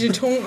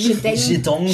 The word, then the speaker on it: Jeton: Ich hätte niemals Jeton aussprechen können. – Jeton.